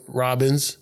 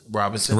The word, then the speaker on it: Robinson: Sylvia Robbins. – Robinson.